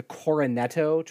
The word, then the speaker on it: Cornetto: I think the Cornetto trilogy, am I saying it right? – Yeah.